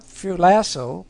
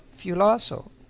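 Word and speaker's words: phulasso (φυλάσσω): Not phulasso, but phulasso. P-H-U-L-A-S-S-O, long O.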